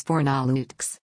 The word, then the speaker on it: Fornalutx: The random article for Saturday, July 3, 2021 is Fornalutx. Fornalutx, Catalan pronunciation, Fornalut, is a mountainous municipality and village on Mallorca, one of the Balearic Islands, in Spain.